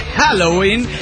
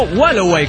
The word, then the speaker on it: Halloween: Halloween se pronuncia Halloween y es en perfecto inglés. Y no Wallaway, como tú dices.